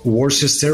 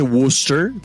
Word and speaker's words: Worcester: El primero fue Lee Richmond en 1880 del equipo Worcester Ruby Legs. – Sí, se dice Worcester, ¿no? Para cuando quieras volver a pronunciar esa palabra.